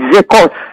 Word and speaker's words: Secos: But it's called Zecos, S-E-C-O-S, with an accent on the E, Zecos.